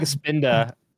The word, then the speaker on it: Spinda: I think it was like Spinda, and you're like Spinata.